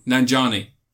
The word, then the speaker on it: Nanjiani: Nanjiani.